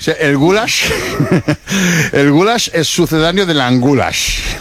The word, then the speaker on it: goulash: Es el goulash es pronunciado así, goulash.